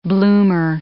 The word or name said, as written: bloomer